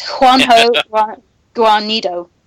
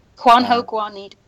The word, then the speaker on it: Juanjo Guarnido: It's Juanjo Guarnido. – There you go. – Juanjo Guarnido.